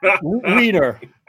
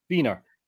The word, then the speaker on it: Wiener: Like, now he knows that the W is actually spoken like a V over here. It goes down easier, you know? It's just... – So how do you say wiener? – Wiener. – Okay. – Pretty much. You just exchange the W sound with the V sound.